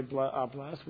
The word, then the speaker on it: blessed: I don't know why we make it into two syllables when we say blessed.